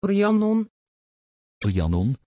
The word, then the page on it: Rhiannon: Dutch